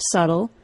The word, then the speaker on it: subtle: I believe this theory of the Latinizing of the word subtle because that word is not the only word that gained a silent B. Two other words that were messed up by the scholars were the words debt, D-E-B-T, and doubt, D-O-U-B-T.